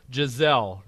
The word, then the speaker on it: Giselle: I remember when I pronounced this on the show and I totally botched it, but it is Giselle.